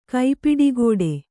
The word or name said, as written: ಕೈಪಿಡಿಗೋಡೆ